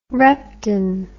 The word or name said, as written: repton